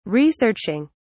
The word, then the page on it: researching: British English